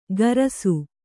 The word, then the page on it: ಕರಸು: karasu